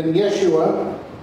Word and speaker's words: Yeshua: Which in Hebrew would have been Yeshua, or possibly Yahshua. Some people think that would be a more accurate way of saying it. But most of the time when you hear people pronounce it now, they'll say Yeshua.